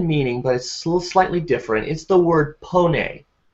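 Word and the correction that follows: pnoe: Excuse me, not pone, noe. I said that wrong. It has a P in front of it in the Greek, but it's noe.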